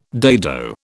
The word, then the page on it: dado: British English